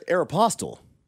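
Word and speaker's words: Aéropostale: Aeropostale? That's how you pronounce that? – That's how I pronounce it. How do you pronounce it? – I always said Aeropostale, but... – Yeah, it's Aeropostale. – Ooh.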